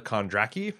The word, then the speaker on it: Kondracki: Yeah, it was directed by Larissa Kondracki, I think is how you pronounce it. Dothraki, yeah.